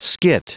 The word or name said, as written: skit